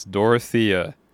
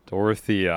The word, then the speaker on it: Dorothea: I'm sure every Taylor Swift fan is going to be very disappointed in me for not pronouncing it correctly. It's Dorothea. Dorothea. I'm saying it properly this time.